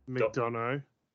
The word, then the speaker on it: McDonough: So it is Carmelo Hayes versus JD... – McDonough. – McDonough gosh, some of these pronunciations are weird.